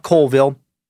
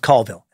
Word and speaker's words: Colville: First off, it's not Colville. It's pronounced Colville.